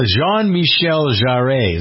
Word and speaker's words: Jean-Michel Jarre: Michael Jean-Michel Jarre, is that how you pronounce it in French, Pierre?